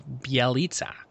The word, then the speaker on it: Bjelica: So Basketball Reference breaks it down phonetically right under his real name. So it's Nemanja Bjelica. Yeah, Bjelica, I think it is.